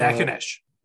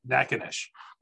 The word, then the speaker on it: Natchitoches: So, Natchitoches. Natchitoches.